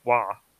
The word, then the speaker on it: Roy: Yeah, Patrick Roy for us Americans that don't want to pronounce it wah.